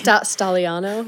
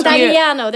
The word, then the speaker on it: Stagliano: Stagliano, like stallion? – Maybe. It's an Italian. No, Stagliano. There's like a G in there.